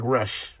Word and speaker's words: Rush: I don't even know who Roosh is. Who's Roosh? – His name is spelled like Rush. – Right. – R-U-S-H. He's a Mexican wrestler.